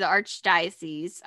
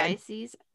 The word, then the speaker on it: Archdiocese: Severide and Seeker go to the Archdiocese. I think I said that right. – Yeah, you did. – Archdiocese.